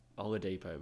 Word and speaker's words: Oladipo: Oladipo, mate.